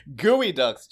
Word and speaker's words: geoduck: This is like geoducks all day, every day. – Gooey ducks, Jathan. It's pronounced gooey ducks.